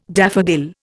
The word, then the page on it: daffodil: Indian